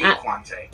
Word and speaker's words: Dequante: Dequante.